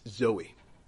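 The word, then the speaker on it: Zoe (ζωή): And the one that is used again and again here is, if you were to pronounce it, it's Zoe. the name Zoe, that's what it's talking about.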